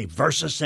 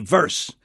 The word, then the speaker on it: versus: "With all due respect, have you noticed nobody in sports knows how to say versus anymore? They say verse.